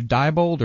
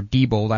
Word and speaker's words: Diebold: It's either Diebold or Diebold.